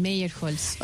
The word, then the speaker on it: Meyerholz: Ah, también es alemán, Meyerholz. – Meyerholz, así se pronuncia. – Sí.